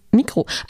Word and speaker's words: Migros: Dann hat die Clubschule Migros jetzt den Migro. richtigen Mikro.